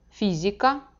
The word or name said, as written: fizika